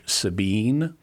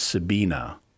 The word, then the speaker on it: Sabine: So if you see like one of a physicist that I follow, her name you might pronounce if it was English, Sabine, but because she's German, it's Sabina because she's got an E on the end of her name.